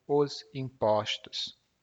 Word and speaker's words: impostos: Uma dessas despesas é o imposto ou os impostos. Perceba bem a diferença na pronúncia. O imposto, os impostos.